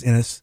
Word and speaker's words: Innes: Neil Innes or Innes, however pronounced his name. I think it's Innes. Innes, yeah.